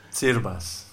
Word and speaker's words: Tsirbas: And it's spelled T-S-I-R-B-A-S. And in Greek, the pronunciation of the T-S is ts, which seems to be a little bit difficult for the Aussies.